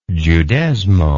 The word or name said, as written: Judezmo